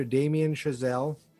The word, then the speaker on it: Damien Chazelle: We're doing from 2014, writer-director Damien Chazelle. Did I say that right? Yeah. – Yes.